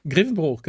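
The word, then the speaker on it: Grevenbroich: Und zwar in, das ist wieder so ein Ort mit, mit OI im Namen, wo man nicht so genau weiß als Außenstehender, wie man das aussprechen soll. Nämlich, ich glaube, Grevenbruch, oder? – Grevenbruch, genau. Das ist auch gar nicht so weit genau.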